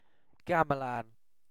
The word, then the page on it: gamelan: British English